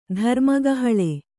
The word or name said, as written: ಧರ್ಮಕಹಳೆ